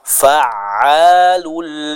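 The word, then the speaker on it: fa'alu (فعّال): Contohnya, fa'alul lima yurid. Fa'alu, fa'alu, ya. Jadi bukan fa Alu, jangan terputus suaranya, tapi huruf ain dibaca dengan suara yang dialirkan. Kami contohkan sekali lagi, fa'alul lima yurid.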